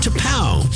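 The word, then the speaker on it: T'Pau: T apostrophe P-A-U, pronounced to pow.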